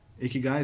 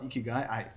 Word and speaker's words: Ikigai: Ikigai, is that how you pronounce it? – Yeah, it is.